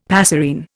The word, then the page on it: passerine: Indian